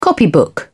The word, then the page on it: copybook: British English